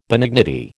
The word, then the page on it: benignity: American English